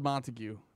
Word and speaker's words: Montague: Spelled Montague, pronounced Montag. – Yeah, Montague spelled Montag said. That's John Montag.